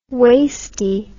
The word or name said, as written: wasty